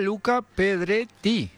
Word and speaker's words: Luca Pedretti: Hola, Luca Pedretti. – Pedretti. Exactamente. – ¿Lo dije bien? A ver, dígalo usted. – Luca Pedretti. – ¿Eso usa doble T? – Doble T, italiano.